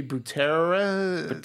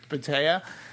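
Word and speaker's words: Boutella: The actress that portrayed her, and I can never get her name right, but she was Sophie Butera. – Butea.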